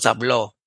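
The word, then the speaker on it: habló: But if it makes you feel good, makes the students feel good at that point in time to say, oh, you put an accent there, you know, make the let's not hablo, es hablo. You have to put the stress on the end to make it past tense for he, right?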